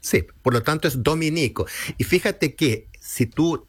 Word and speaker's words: Dominico: Si se alude a los religiosos de la Orden de Santo Domingo, se escribe Dominico, sin tilde en la I.